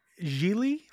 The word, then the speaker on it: Gigli: How do you say it? – Gigli future episode. – I always thought it was Jiggly when I was little. I just thought it was called Jiggly.